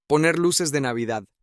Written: poner luces de Navidad

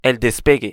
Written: el despegue

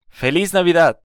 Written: Feliz Navidad

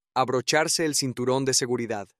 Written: abrocharse el cinturon de seguridad

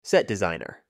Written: set designer

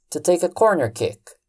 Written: to take a corner kick

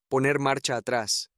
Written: poner marcha atras

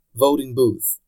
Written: voting booth

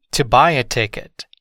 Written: to buy a ticket

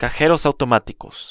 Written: cajeros automaticos

